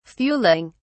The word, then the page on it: fuelling: British English